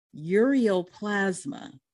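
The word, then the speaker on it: Ureaplasma: Tell me if I'm pronouncing it correctly. Ureoplasma. What in the world is it? And did I say it okay? – Yes, you said it correctly.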